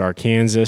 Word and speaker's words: Arkansas: It's pronounced Arkansas.